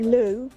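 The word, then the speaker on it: Looe: You pronounce it Lou. – All right. – Like the toilet, okay.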